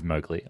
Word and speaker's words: Mowgli: It is Mowgli. I'm sorry. I was mispronouncing it that whole film.